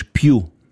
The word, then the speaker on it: più: Und das heißt auf Italienisch più.